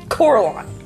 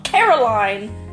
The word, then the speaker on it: Coraline: It's one of my favorite creepy childhood movies, Coraline. Not Caroline, Coraline. The pronunciation is very, very important.